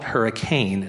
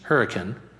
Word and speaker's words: Hurricane: Don't call it Hurricane, it's Hurricane, okay? They're very picky about pronunciation up there.